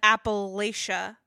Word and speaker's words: Appalachia: The way I've always heard it is like if you say it, how did you say it? – Appalachia. – They'll throw an apple at you. So Appalachia. – So you remember it's Appalachia.